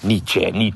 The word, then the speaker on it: Nietzsche: I never pronounce it quite right. It's Nietzsche. So he was, from modern day, what we call the Germany area.